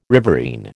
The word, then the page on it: riverine: American English